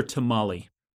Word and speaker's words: tamal: Because it's actually a tamal. – Okay. – But because pluralizing something in Spanish often adds an extra syllable, it's not tamales, it's tamales, because they don't put S's into consonant clusters.